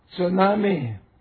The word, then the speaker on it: tsunami: tsunami. Tsunami, that's how you pronounce it in Japanese.